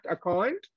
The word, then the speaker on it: A Kind: Is it pronounced A Kind? – Yes, like a pun would imply.